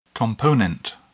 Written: component

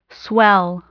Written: swell

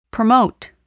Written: promote

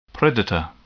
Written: predator

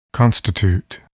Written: constitute

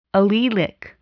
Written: allelic

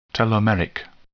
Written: telomeric

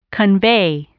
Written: convey

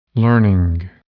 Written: learning

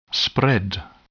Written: spread

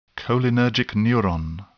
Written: cholinergic neuron

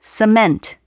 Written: cement

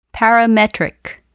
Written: parametric

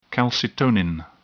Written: calcitonin

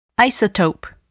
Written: isotope

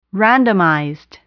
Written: randomized

